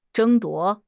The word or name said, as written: 争夺